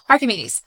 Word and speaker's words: Archimedes: Archimedes.